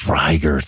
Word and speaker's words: rigor: It's Riger. Thank you. It actually is. did I tell you that? – Yeah. – Yeah. So, I learned that in that case. Like he's like, oh, everyone mispronounces Riger mortis.